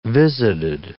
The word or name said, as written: visited